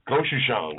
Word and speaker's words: Gochujang: Gochujang, Gochujang.